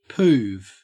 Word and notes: poove: Australian English

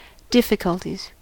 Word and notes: difficulties: American English

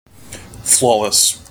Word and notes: flawless: American English

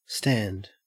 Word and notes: stand: Australian English

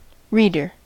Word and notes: reader: American English

